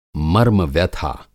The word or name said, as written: मर्मव्यथा